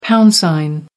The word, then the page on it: pound sign: American English